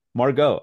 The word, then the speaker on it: Margot: Is, you know, so how do you pronounce it? Margaret? Margaret? – Margot. – Oh, wow. It's French.